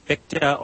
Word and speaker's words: Fichte: People like Johann Gottlieb Fichte, or Fichte, I think both pronunciations are acceptable.